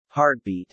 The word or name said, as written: heartbeat